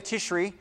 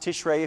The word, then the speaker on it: Tishri: So Rosh Hashanah is always held on the first day of Tishri, sometimes pronounced Tishrei if you prefer, the seventh month of the Jewish calendar.